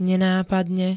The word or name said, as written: nenápadne